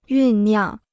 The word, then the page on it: 酝酿: yùn niàng